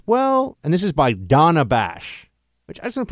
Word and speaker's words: Dana: Everyone calls her Dana because her name is spelled Dana. But you're supposed to know that her name is Donna. Now, you might be saying, Buck, if her name is D-O-N-N-A, of course, no, it's D-A-N-A.